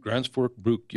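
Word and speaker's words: Gränsfors Bruk: You got your knives by Alaska Knives, and then is it Grandsfjord Bruk? Is that how you pronounce it? – Grandsfjord Bruk.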